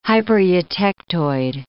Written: hypereutectoid

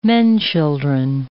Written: men-children

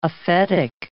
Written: aphetic

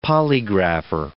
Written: polygrapher

